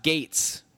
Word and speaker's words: Gaetz: But I'll just tell you, I mean, his last name is pronounced like it's pronounced Gates. Dumbass. It's spelled like G A E T, sometimes Z, I think.